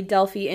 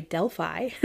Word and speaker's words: Delphi: But before I share those, quick update on the Delphi, Indiana, sorry, Delphi, I always say it wrong,